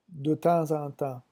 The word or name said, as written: de temps en temps